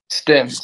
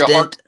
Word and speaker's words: Stindt: Stindt. – Stindt? – Like Stindt.